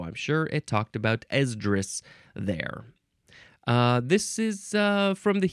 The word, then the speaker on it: Esdras: Esdras. That's how you pronounce it. Esdras. With a capital E. ESDRAS.